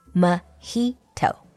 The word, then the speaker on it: mojito: a lot of people think it's pronounced mojito. But actually, here you need to follow the Spanish pronunciation. J is pronounced as an H, so it's mojito.